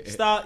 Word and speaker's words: Staar: So star is spelled STAAR.